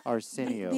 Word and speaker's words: Arsenio: Okay, the word is spelled A-R-S-E-N-I-O. – Oh, say it. – Say that correctly. – Arsenio. – Okay, Angela. – Good. Close enough.